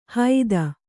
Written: ಹೈದ

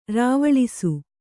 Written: ರಾವಳಿಸು

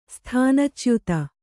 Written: ಸ್ಥಾನಚ್ಯುತ

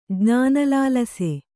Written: ಜ್ಞಾನಲಾಲಸೆ

